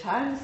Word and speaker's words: Chan: Et Chan, c'est donc la prononciation chinoise, c'est un terme qui se prononce en japonais Zen.